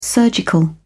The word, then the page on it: surgical: British English